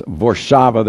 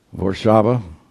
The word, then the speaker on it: Warszawa: Actually, it's pronounced Warszawa there. All your W's are pronounced with a V. Warszawa.